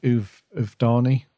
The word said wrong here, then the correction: Uvodni: the Uvdani race or something like that. – Yeah. – The Uvodni. Yeah, that's it.